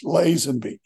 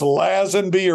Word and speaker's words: Lazenby: There's a village, Lazenby. I don't know if it's Lazenby or what, but I'm not really particular about how people pronounce my name.